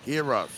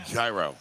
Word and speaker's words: gyro: Say it right. – Heroes. – Yes. – All right, whatever. All right, gyro. – No. – The American way.